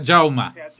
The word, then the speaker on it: Jaume: Encontré uno que hablaba muy bien de la relación del rey Jaime I con o Jaume, como dicen, creo que es en catalán, ¿no? – Sí, Jaume. – ¿Cómo? – Se pronuncia Jaume.